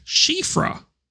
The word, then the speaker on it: Siofra: I found out recently it's pronounced Shifra. Shifra. Um, Shifra which is a popular Irish name that means elf or fairy.